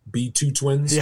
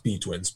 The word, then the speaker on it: B2wins: Everyone always wants to say B2 twins. – Yeah. But it's no, it's B2 wins. – Or B2 wins, yeah. But it's just it's pronounced B twins, but it's they got cheeky with the English language and thought, why don't we spell it with a 2 instead B-twins.